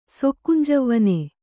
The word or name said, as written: ಸೊಕ್ಕುಂಜವ್ವನ